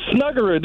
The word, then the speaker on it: Snuggerud: Stop it. It's Snuggerud. These are common names. Come on. Pronounce them right, Doug.